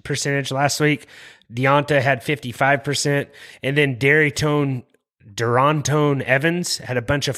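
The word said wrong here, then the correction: Darrynton: How do you say that? – Darrington.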